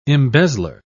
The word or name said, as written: embezzler